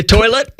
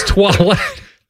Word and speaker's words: toilette: each Eau de Toilette is crafted to uplift the senses and celebrate Mediterranean nature at its finest.